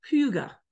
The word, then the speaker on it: hygge: If you see it, it's HYGGE. IKEA even has a mat that says it, and it's pronounced puga.